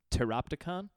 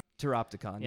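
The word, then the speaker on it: Pteropticon: I believe it's Teroptikon. – Teroptikon. – Yeah, like silent P, like pterodactyl.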